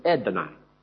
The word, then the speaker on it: Adonai: That Adonai is really a very short vowel sound. It's not really it's Edonai. Edonai. And the last vowel is actually an ah sound, but it's followed by a Y, which makes it sound like I.